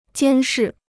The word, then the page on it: 监视: jiān shì